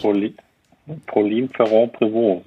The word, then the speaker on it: Pauline Ferrand-Prévot: Den Namen musst du jetzt nochmal aussprechen, bevor ich ihn falsch sage. Wie heißt sie richtig? – Pauline Ferrand-Prévot, so spreche ich sie zumindest aus. Sie hat sich bisher noch nicht beschwert.